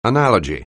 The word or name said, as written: analogy